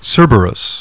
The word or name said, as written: CERBERUS